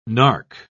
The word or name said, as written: nark